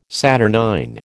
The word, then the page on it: saturnine: American English